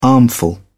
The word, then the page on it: armful: British English